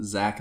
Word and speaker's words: Zack: Zach is spelled Z-A-C-K.